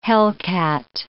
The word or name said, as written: hellcat